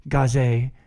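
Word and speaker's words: Gauze: There are many that came after, but there's only one Gaz, or Gaze, as I've been told is the proper pronunciation.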